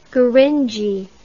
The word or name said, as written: gurindji